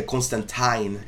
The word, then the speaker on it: Constantine: E eu começo falando, obviamente, da Johanna Constantine, que foi chamada pela pronúncia correta, de acordo com o New Game, que é Constantine.